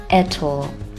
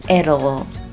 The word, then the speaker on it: it'll: 另一种念法是可以把两个单词缩读成 it'll, 或者是 it'll, 都是可以的。